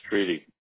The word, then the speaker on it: Streety: Streety.